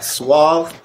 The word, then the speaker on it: Suave: At Suave of Nightlife.